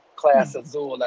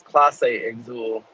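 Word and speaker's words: Clase Azul: I'm gonna start with this Ciroc, and then I got some Class Azul at the crib. – Some who? – Class Azul. – What's that? – That's that tequila. – How you say it? – I think it's Classe Azul.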